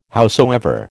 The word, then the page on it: howsoever: American English